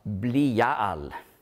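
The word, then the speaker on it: Blial (בליעל): Eigentlich auf Hebräisch spricht man aus Blial.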